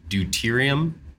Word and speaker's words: deuterium: You mentioned, and I'm going to say this wrong probably, deuterium? – Yeah. – Did I say that right? – Yeah. – Yeah, you got it.